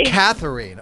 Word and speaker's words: Katharine: It's Katharine, but that's okay. – Katharine. Okay, Katharine. I want to get it right. As somebody whose name is spelled weirdly, I get very upset when people get it wrong. Katharine. Now I got it.